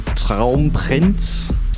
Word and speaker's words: Traumprinz: He's been releasing also under the name Traumprinz.